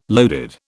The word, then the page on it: loaded: British English